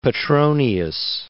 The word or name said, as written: Petronius